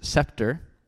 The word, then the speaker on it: scepter: And I mentioned his scepter, which is a king's staff. Spelled S-C-E-P-T-E-R, scepter. But to my horror and to my shame and to my embarrassment, I pronounced it scepter.